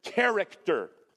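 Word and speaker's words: Character (χαρακτήρ): The word, exact imprint, is the word character. That's the Greek pronunciation. Character. That's how it sounds.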